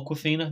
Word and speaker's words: Awkwafina: Um, Bart Awkwafina, who or Awkwafina, I think it's pronounced,